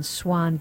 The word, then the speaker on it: Xuan: And I always have to spell it because she doesn't spell it like the regal bird. She spells it X-U-A-N, Swan, Big Blue Ocean.